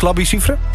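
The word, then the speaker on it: Labi Siffre: En die eerste is de nieuwe van Alok met Labi Cifre. En die ken je dus. Zeg ik dat goed, Labi Cifre? – Ja, groot vriend.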